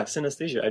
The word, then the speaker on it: synesthesia: Now, in your book, you mention synesthesia. Am I pronouncing that right? – Yeah, synesthesia.